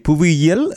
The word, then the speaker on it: புவியியல்: புவியியல் என்று சொன்னால் அது புவி ஈனா வந்து யானா வருவது போல வரும். புவியியல் என்று சேர்ந்து வருகிற போது ஈனா யானா இல்லைன்னா சேர்ந்து வரும். அது பிரிக்கிற போது மட்டும்தான் புவி கூட்டல் இயல் என்று வரும். எனவே புவியியல் என்பது சரியான உச்சரிப்பாக இருக்கும்.